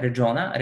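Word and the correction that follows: Alfonso Gomez-Rejon: Alfonso Gomez Rejon.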